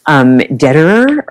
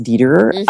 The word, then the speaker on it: Dederer: Okay, so my absolute favorite was a memoir by the writer Claire Dederer, or her name might be pronounced Dederer, D-E-D-E-R-E-R, called Poser.